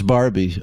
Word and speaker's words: Barbie: And then I've always said Borby. Is that how you say Borby? – Borby? – Oh, it's Barbie. Oh.